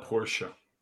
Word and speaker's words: Porsche: Don't ever call it Porsche. – That's how it's pronounced. – He's got so. One. He's got one. – Porsche. – There's an E. – Anyway, whatever. – Porsche.